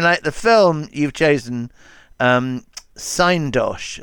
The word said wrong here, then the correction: Synecdoche: I think it's called Synecdoche.